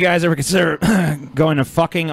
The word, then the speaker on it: Fucking: Dude, there's a sign for fucking. – It rhymes with booking, apparently. So apparently it's pronounced fucking.